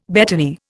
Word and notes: betony: Indian

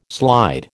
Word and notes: slide: American English

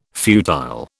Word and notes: futile: British English

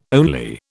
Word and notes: only: British English

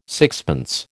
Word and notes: sixpence: American English

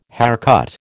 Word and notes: haircut: American English

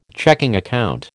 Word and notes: checking account: American English

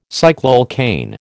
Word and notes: cycloalkane: American English

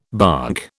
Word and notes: bonk: British English

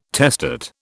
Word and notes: testate: British English

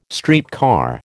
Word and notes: streetcar: American English

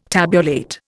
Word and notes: tabulate: Indian

